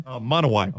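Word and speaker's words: Monowi: Monowai.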